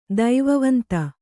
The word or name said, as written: ದೈವವಂತ